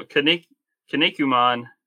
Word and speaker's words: Kinnikuman: Well, Kinnikuman, I mean, it's just kind of some of those, these are just pronounced the way they're spelt.